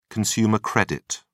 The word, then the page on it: consumer credit: British English